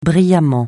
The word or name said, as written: brillamment